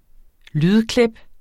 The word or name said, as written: lydklip